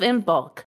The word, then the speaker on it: Imbolc: I've heard someone say Imbolc instead of Imbolc. Unlike Samhain, there is an easier way for someone who is new and mispronounces it for the first time to remember the correct way. Here it is. We go to Costco to buy things in bulk.